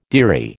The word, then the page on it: dearie: American English